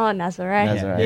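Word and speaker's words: Nazaré: It was at Nazare. Or Nazir. I don't know. How do you pronounce it? – Oh, Nazare.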